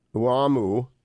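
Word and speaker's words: Oumou: In midsummer of 2018, Uamu Kanute, I believe that is how it is pronounced, O-U-M-O-U, O-U-M-O, Kanute, a black student at Smith College, recounted a distressing American tale.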